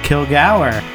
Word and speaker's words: Kilgour: Kilgour. – There was some discussion about the pronunciation, but according to David, that is how his mother pronounces it, and that's how we will pronounce it also.